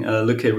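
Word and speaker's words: Lykke: How is it pronounced? Lücke? – It's called Lücke, yeah.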